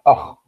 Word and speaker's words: أخ: كلمة أخ ليست مشددة الخاء طبعا في العامية بنقول إيه أخ ولكن لما أنطقها أقول أخ أخ